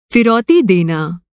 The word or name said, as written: फिरौती